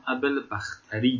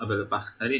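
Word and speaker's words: ابوالبختری: در مورد تلفظ لفظ اسم راوی چه این روایت که دیروز خوندیم، من نگاه کردم ابل بختری درسته. دیروز نمیدونستم رحمون این قسمت تلفظش ابل بختری حل.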